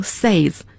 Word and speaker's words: says: 那么我们要注意在说英语的过程中 ,say 是代表普通的动词原形 ,say, 单数第三人称 ,S-A-Y-S, 不能读成 says, 要读成 says, 发小 a 的声音像床 ,bed 那个发音。